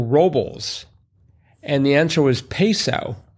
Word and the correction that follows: Paso Robles: Paso. Paso. – It's pronounced paso with a, okay.